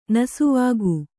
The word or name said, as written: ನಸುವಕ್ಕೆ